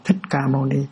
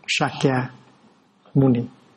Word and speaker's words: Sakyamuni: Thì tên của Ngài, ngày xưa là mình đọc theo người Hoa, mình đọc là Thích Ca Mâu Ni. Bây giờ mình phải đọc lại cho đúng. Sakyamuni, Bụt Sakyamuni, đó là tên đúng của Ngài.